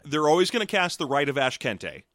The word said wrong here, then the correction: Ashkente: Because I'm sure I'm pronouncing that wrong. I've always felt like there's probably a joke in that word that I'm not seeing. – It's Ashkenta. – Ashkenta? – Yeah, for Ask Enter.